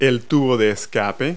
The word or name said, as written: tailpipe